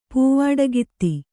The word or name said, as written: ಪೂವಾಡಗಿತ್ತಿ